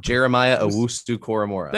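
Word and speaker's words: Jeremiah Owusu-Koramoah: Jeremiah Owusu Koramora.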